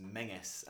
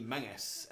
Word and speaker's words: Menzies: So basically, my last name is spelled M-E-N-Z-I-E-S, and traditionally that's supposed to be pronounced Miggis.